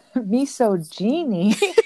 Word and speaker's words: misogyny: Did I say it right now? – No. – Oh, God. No, let me say it again. – Misogyny. – Misogyny. Sorry. I mean, okay, misogyny. I got it. It's spelled, it just, like, it's got O-G-Y-N in it. And I was like, yeah, it threw me.